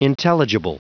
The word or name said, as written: intelligible